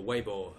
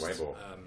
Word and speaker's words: Weibo: who are the company who run Weibo, or Weibo as I was told it's pronounced. – Weibo.